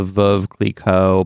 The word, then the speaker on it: Veuve Clicquot: Veuve Clicquot Polo Classic, which was in New York. – Flawless pronunciation of that champagne brand, by the way.